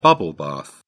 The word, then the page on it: bubble bath: British English